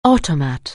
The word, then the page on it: automat: anglais